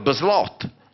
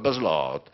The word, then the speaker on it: baslot: Ma ridete perché lo pronuncio male, oppure... Beh, insomma, al baslot, con una U lunga un po' aperta, al baslot, il catino.